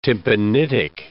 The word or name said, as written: tympanitic